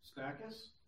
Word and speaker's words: Stachys: How do you say that? – Stachys? – Yeah, that works.